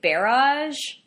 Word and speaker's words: barrage: Barrage. I had been pronouncing it barrage. – Oh, really? – And Jeremy was like, it's barrage.